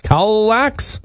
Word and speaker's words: Kallax: For example, how do you say K-A-L-L-A-X? Kallax? – Kallax.